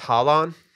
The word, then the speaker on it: Haaland: It's pronounced Haaland.